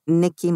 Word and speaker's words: Nicky: So it's L-A and then Pizarra is P-I-Z-A-R-R-A. And then C-O-N for con Nicky Mondellini. So, right. And Nicky is N-I-C-K-Y.